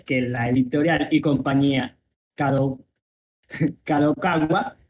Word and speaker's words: Kadokawa: así como la nueva oficina de la editorial Kadokawa.